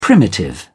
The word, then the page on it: primitive: British English